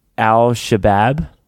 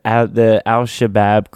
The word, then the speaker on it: Al-Shabaab: people, like over 100 people rescued just dealing with this attack from Al-Shabaab.